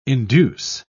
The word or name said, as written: induce